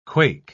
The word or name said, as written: quake